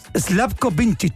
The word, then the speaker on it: Slavko Vincic: Slavko Vincic.